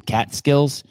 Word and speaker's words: Catskill: How do you say the word cat? Cat skills? Cat skills or with a G or skills? Like they got skills. – You have it. The Catskill Mountains. Correct. Yeah.